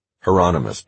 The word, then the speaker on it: Hieronymus: "Hieronymus," Vash said.